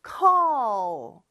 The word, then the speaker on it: call: call. 자, 요거 발음할 때 조금 어려운데요. C-A-L-L이잖아요. 요 L 발음을 할 때 조금 어려워요. 혀가 윗니 뒤쪽에 쭉